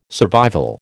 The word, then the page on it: survival: American English